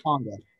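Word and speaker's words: Khyiris Tonga: Is it Kyrus Tonga? – I'm not 100% sure. I know of this guy and I like his tape. I just have to double check exactly how to pronounce it. So give me 1 second here as I look up the pronunciation. I don't want to butcher that. – No worries. Kyrus. Take your time. – Kyrus. There Kyrus. – We go. – Perfect. Kyrus Tonga.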